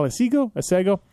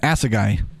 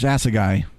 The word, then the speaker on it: Assegai: That's how you pronounce it. Assegai. – Not Asego or Asego? – Nope. AI is the last two letters. Assegai.